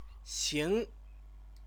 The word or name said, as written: xing